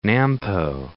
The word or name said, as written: Nampo